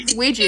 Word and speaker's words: Ouija: Is that how you pronounce that? Ouija board? – Ouija.